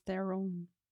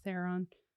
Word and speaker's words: Theron: Charlize Theron. I'm being fancy. I mean, most Americans just say Theron. – Yeah. – But I think technically she's South African, so.